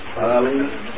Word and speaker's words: Philemon: Well, you know, I don't know how y'all pronounce this. Philemon? Or Philemon? – Philemon.